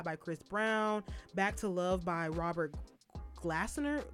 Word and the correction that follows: Glasper: Glasper. I probably say his name wrong. – Where you got the N from? – Huh? – Where you got an N from? – Okay.